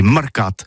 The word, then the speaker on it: marcates: En Gascogne toulousaine, bah, du côté par exemple de l'île des Baches, c'est l'île Jourdain, pour parler du marché, vous prononcez les marcates.